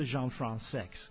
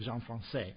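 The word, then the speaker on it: Françaix: And we're going to go right from this Brian Robison piece to the Jean-Francex, who, by the way, seemed to insist that his name be pronounced that way instead of Jean-Francais.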